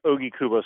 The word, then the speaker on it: Ogikubo: It's actually pronounced Ogikubo Station.